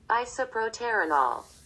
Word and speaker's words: isoproterenol: Isoproterenol. Am I saying it right? Let's figure it out. – Isoproterenol. – Very good.